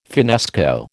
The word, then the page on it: finnesko: American English